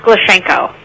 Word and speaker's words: Glushenko: It's pronounced Glushenko. – Ah, well, that's easy. – Yeah, hard to spell, easy to say.